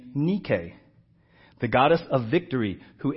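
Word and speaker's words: nike (νίκη): That word in the Greek is nike. If you saw it written on paper, you would say, hey, he means Nike. N-I-K-E is pronounced in the Greek, nike. It is the Greek goddess of victory. That is what it means. N-I-K-E means victory.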